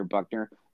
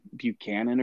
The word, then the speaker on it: Buchner: David Pollack pronounced his name wrong, called him Buchanan or something like that.